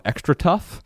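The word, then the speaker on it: Xtratuf: What Becca wears and what Ella wears as well is a company called Extra Tough. Mm. And it's spelled really funny. I think it's X-T-R-A-T-U-F, all one word.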